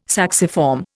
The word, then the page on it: sacciform: Indian